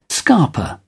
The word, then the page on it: scarper: British English